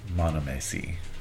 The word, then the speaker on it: monomacy: And it's called monomacy. – Spell that. – M-O-N-O-M-A-C-Y.